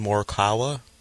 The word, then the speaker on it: Morikawa: How do you say his last name? – Colin Morikawa. – Yeah. – Is that it? – Morikawa. – Yes. Yeah.